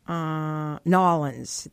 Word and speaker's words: New Orleans: And it reminds me, Megan, of Singapore, where it's just like New Orleans. And they don't say it that way either. They call it Nolans. That's how they pronounce it.